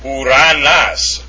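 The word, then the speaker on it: Uranas (οὐρανός): And the last one is omicron upsilon, pronounced u as in soup, or in the important form uranas. We have to get the proper accent there. It's on the last omicron. Uranas.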